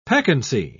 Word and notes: peccancy: British English

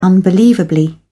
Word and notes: unbelievably: British English

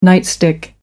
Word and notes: nightstick: American English

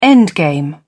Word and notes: endgame: British English

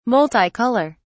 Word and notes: multi-color: British English

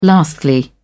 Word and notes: lastly: British English

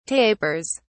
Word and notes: tapers: British English

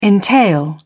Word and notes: entail: British English

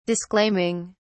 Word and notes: disclaiming: British English

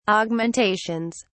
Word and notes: augmentations: British English

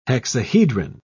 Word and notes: hexahedron: British English

